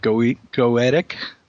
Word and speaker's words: Goetic: And we haven't even started to scratch the surface of Enochian or, will I pronounce it right, Goetic? – Yeah, that'll do.